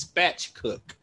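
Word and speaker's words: spatchcock: I could be pronouncing it wrong because I'm chicken spatch. I'm sorry, it's spatch cook.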